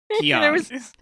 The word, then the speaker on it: Heian: What did I say? Heian? No. Heian. Oh, Heian. – Heian. There's an extra N right in the middle.